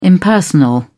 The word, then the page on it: impersonal: British English